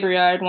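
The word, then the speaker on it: Brouillard: On both Twitter and Instagram, my handle is just my name, Lea Vreelard, one word. So that's LIABROUILLARD. If any of you speak French, it's the word for fog.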